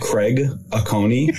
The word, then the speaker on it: Craig Acone: Craig Acone.